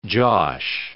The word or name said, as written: josh